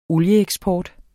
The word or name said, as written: olieeksport